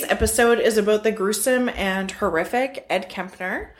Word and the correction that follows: Kemper: That's not his name. – You mispronounced it. – Ed Kemper. – Kemper.